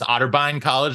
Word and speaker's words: Otterbein College: You guys were, you know, play D3 captains, Otterbein College.